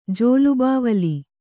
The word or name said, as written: ಜೋಲುಬಾವಲಿ